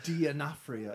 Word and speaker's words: D'Onofrio: Is that how you pronounce his last name? – D'Onofrio. – Yeah.